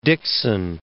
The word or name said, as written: Dixon